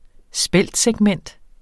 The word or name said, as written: speltsegment